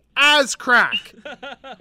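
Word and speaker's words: Azcrac: It's A-Z-C-R-A-C. Ascrack.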